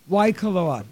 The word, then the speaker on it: Waikoloa: Waikoloa. Waikoloa.